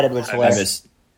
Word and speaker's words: Edwards-Helaire: Yeah, Clyde Edwards-Helaire, I think, if I'm mispronouncing that last name. But yeah, you're right. Clyde Edwards-Helaire.